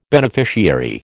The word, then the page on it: beneficiary: American English